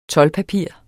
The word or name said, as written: toldpapir